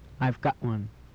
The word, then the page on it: I've got one: English